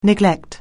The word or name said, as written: neglect